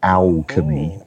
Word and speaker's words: Owlchemy: Talking of vacations, Travis, I noticed that a company called Alchemy, now they spell that O-W-L-C-H-E-M-Y, Alchemy.